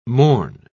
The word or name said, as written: mourn